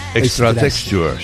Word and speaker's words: Extra Texture: El título del disco, tú lo pronuncias muy bien. – Extra texture, sí. – Correcto, sí señor.